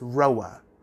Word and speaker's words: RHOA: Some people pronounce it as Roa.